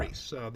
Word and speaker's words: Rhys: It is pronounced yeah. – Rhys.